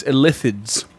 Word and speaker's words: Illithids: It is Illithids. With an emphasis on the second syllable.